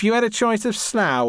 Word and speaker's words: Slough: Slough, which rhymes with cow, is about 30 kilometres from central London.